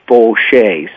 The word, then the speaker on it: Faucheau: You want to remember, it's spelled F-A-U-C-H-E-A-U, but down in Louisiana, that is Fauché.